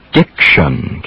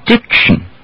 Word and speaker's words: diction: Yes, like in the word diction, T-I-O-N or double S-I-O-N usually spell shun, or actually shin or shin. Most Americans will hardly pronounce the vowel at all in that syllable. They'll say diction. You repeat that? Diction.